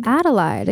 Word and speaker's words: Adelide: Adelide.